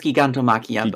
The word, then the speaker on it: Gigantomachia: Because wasn't uh, Giganto or Giganto? How would you say it? – I think it's Gigantomachia.